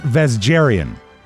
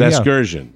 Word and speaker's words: Vasgersian: Vasgerian? – No, that's not how you pronounce it. – I guess he's spelled V-A-S-G-R. – I think it's Vasgersian. – Oh, Vasgersian could be. – Vasgersian. – Yeah.